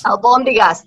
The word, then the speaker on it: albóndigas: The albondigas, I'm terrible at pronouncing words. – Albóndigas, the meatballs, yeah, our prepared meatballs, yeah.